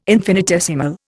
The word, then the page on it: infinitesimal: Indian